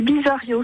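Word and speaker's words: Bize-Arriot: Mon nom, c'est Bizario, c'est un nom composé. – Oui. – Ça s'écrit Bize, B-I-Z-E. Il y a un trait d'union et A-2-R-I-O-T.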